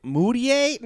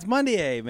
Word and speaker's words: Mudiay: Moutier? No, it's Mudiay, man.